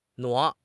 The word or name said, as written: 挪